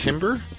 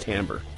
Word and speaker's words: timbre: Timber? – No, it's spelt timber, but it's pronounced timbre in the business of music.